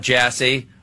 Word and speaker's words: Jassy: Now, I know nothing about Jassy other than his last name rhymes with Sassy.